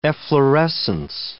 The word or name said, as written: efflorescence